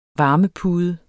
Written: varmepude

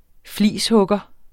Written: flishugger

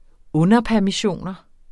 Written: underpermissioner

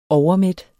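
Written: Overmæt

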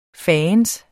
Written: fandens

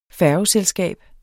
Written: færgeselskab

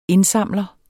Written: indsamler

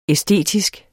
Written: æstetisk

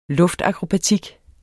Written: luftakrobatik

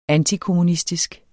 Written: antiskating

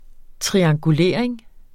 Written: triangulering

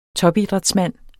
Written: topidrætsmand

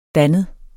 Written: dannet

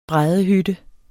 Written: bræddehytte

